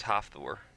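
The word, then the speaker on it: Hafþór: Yeah, I figured out how to say the dude's first name, his real life first name. It's Hopthor. – Hopthor. – Like the joke is that it's like half of Thor was the joke that I had read about. That's sort of the way it's pronounced, Hopthor.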